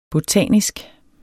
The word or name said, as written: botanisk